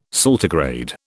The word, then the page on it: saltigrade: British English